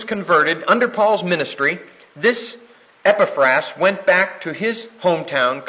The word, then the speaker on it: Epaphras: There is, however, a very important man in this church, and his name is Epaphras, E-P-A-P-H. R-A-S, pronounced Epaphras.